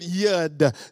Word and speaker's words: yad: So the word hand in the Hebrew is the word yad. It's spelled Y-A-D, pronounced Y-A-W-D.